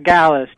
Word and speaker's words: Galus: Gallus, just like Dallas. – Just like Dallas. G-A-L-U-S, Jeremiah Gallus.